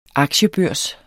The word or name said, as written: aktiebørs